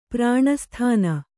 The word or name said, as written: ಪ್ರಾಣಸ್ಥಾನ